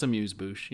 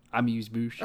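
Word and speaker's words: amuse-bouche: No, I mispronounce it. It's amuse bouche. You're right. You're good. Amuse bouche.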